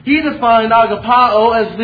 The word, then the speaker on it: agapao: It is the word, and I know it sounds funny to our ears because it's so foreign to us, but it's agapao. Agapao is how it is pronounced, and a more famous term or pronunciation is agape. That's a very similar word to agapao, but they're just a little bit different.